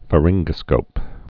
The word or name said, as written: pharyngoscopes